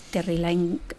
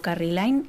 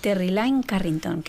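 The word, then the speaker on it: Terri Lyne Carrington: Terry Lyne, a ver si lo digo bien, que a veces digo Carrie Lyne y entonces ya me he liado. Terry Lyne Carrington, que estuvo aquí hace yo creo que un par de años.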